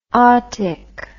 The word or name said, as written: otic